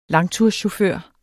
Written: langturschauffør

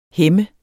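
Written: hæmme

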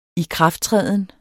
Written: ikrafttræden

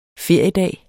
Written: feriedag

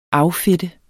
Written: affedte

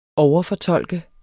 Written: overfortolke

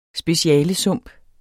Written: specialesump

